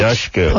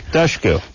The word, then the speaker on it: Dushku: Tush is easy, but Dush is not. – It's pronounced like it's spelled, if you think about it. – Yeah, it is. – D-U-S-H, P-U-S-H. – Dushku. – Push, Dush. – Yeah. – Push. – Cool. – There you go, push. – Yeah. You know what I'm saying? – Yeah. Dushku. – Nice. – Yeah, it is pronounced exactly how it is.